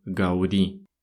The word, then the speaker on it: Gaudí: В Испании ударение ставится на последний слог «Гауди». Не «Гауди», как мы привыкли это говорить, а именно «Гауди».